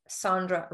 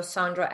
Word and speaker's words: Sandra: So everything that I use on social media is Sandra Radav. So Sandra, S-A-N-D-R-A. And Radav is R-A-D-A-V.